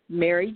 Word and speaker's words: Mary: Okay, well, I'm on Instagram and Facebook, and on Facebook, my name is simply Mary Joyner, and I'm going to spell that out, which is M-A-R-Y.